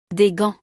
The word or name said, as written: Des gants